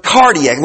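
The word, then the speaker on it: kardia: Understand that that is coming from a Latin word, cardiac, was spelled with a K, K-A-R-D-I-A, cardia,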